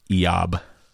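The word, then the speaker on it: Iyov: Yeah, the Hebrew, it looks like it is Iyab, is how you'd say it in Hebrew. – Iyab.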